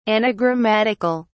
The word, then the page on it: anagrammatical: British English